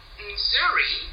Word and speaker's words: Nzuri: Nzuri is a Swahili word.